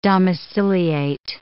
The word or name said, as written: domiciliate